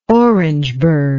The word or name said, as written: Orangeburg